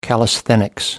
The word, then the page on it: callisthenics: American English